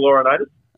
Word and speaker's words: Laurinaitis: Wait, what was his name? – Joe Laurinaitis. – Ah, shit. Yeah, you said it right that time.